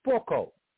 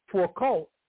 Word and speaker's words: Foucault: The term was first introduced into political discourse by the French philosopher and historian Michel, M-I-C-H-E-L, or Michael, either way you want to pronounce it, Foucault, okay, who died in 1984. As professor of the history of systems, Foucault explored its genesis in his lecture series of 1975 to 79.